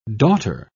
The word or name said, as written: daughter